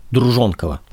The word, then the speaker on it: Друженкова: Кстати, на днях я узнал от историка Нины Обнорской, что правильно говорить «Друженкова». Ну что ж, постараюсь придерживаться этой нормы, несмотря на то, что во всех публикациях эта фамилия пишется через «Е».